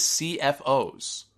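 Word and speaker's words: CFO$: Yes, all this time I've been saying CFO Money. Apparently I was wrong. The correct way to say their name is CFOs. That's straight from their own Twitter.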